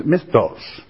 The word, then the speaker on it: misthos: The word in the Greek again for rewards is mythos. Spelled M-I-S-T-H-O-S. Pronounced mythos.